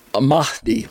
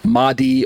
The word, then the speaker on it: Mahdi: But he has a parallel with Islam's Mahdi, M-A-H-D-I, Mahdi or Mahdi, depending on who you've listened to on the internet, how they say it.